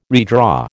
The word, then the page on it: redraw: American English